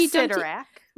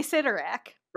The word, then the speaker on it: Cyttorak: No, in my head, I say Sidorak. – Right, that's what I like to say. I've heard Sidorak. Mm-hmm. And I think I've heard Chitterack.